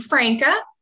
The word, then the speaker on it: Franca: I always say it Franca. Yeah, it's Franca.